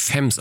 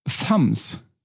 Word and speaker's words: Femmes: Ja, ich habe da wirklich sehr intensiv mal geforscht und die Lautschrift sagt Femmes. – Ja. – Also sie hat denselben Laut wie bei Violent. Dieses Femmes. Violent Femmes.